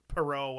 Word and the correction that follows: Petiot: whatever the fuck it is. – No, yeah. – It's Petiot. – Yeah, some unpronounceable French bullshit. – It's Petiot.